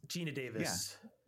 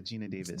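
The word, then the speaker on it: Geena Davis: Am I saying her name wrong? – Gina Davis. – Yeah, Gina Davis.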